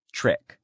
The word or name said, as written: trick